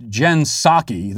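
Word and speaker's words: Jen Psaki: EN says, it's pronounced Jensaki. The P is silent, man. Well, we'll agree to disagree on that one, I suppose.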